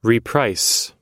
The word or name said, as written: reprice